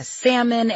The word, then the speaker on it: salmon: They order the salmon and pronounce the L, and you're like, how the hell has my life come to this?